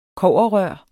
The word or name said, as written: kobberrør